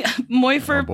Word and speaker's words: Morfydd: Morfydd, but it's spelled M-O-R-F-Y-D-D. So that's Morfydd? Why we don't know what's going on. – I also was looking at the phonetic pronunciation and still couldn't do it. – Morfydd. I would Morfydd. Never guess that that was a T-H with the two D's.